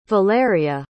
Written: valeria

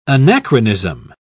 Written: anachronism

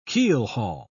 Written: keelhaul

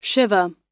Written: shiva

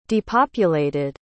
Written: depopulated

